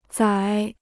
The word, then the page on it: 宰: zǎi